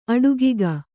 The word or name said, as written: ಅಡುಗಿಗ